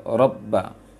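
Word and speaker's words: Robba (ربَّ): Itu robba di ayat ketiga. Robba, jangan terlalu cepat bacanya nanti. Fal ya'budu robba, itu belum, jadi tahan tasydidnya.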